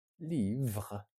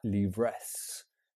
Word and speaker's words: livres: A, we have, des, some, livres, books. Remember, the S is silent, not livres.